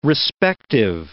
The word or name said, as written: respective